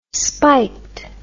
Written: spiked

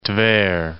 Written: Tver'

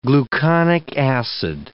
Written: gluconic acid

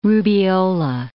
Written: rubeola

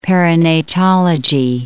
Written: perinatology

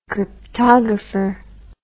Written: cryptographer

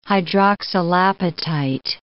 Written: hydroxylapatite

